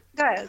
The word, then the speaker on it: Gael: w ogóle jak się wymawia to imię? To jest Gael, tak? tak Gael, tak. Mhm.